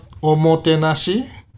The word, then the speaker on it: omotenashi: How do you say that again? – Omotenashi. – Omotenashi. Omotenashi. – Yes.